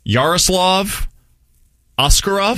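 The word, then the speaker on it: Yaroslav Askarov: Yaroslav Oskarov.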